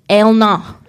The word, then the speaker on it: Eleanor: Eleanor. – Jodelina Eleanor Mitchell. – Except it's my nana's name. My family are working class Scottish people, so it's actually pronounced Elna.